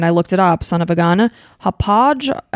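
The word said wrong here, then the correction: Hauppauge: I i i know I'm saying that wrong. – I'm I think sorry. It's Hapag. Yes, thank you. It is.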